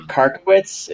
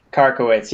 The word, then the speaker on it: Karkiewicz: Is it Karkiewicz? – It is Karkiewicz. Great job on the first attempt. – Karkiewicz.